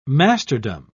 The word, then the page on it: masterdom: British English